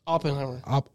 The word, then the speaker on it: Oppenheimer: Oppenheimer.